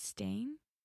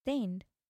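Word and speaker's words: Staind: Is it stain? – Stained. – Stained or stain? – I think it's stained.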